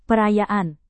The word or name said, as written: perayaan